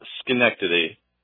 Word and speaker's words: Schenectady: It's pronounced Schenectady. – Schenectady. Schenectady. – Yes. – Okay, thank you.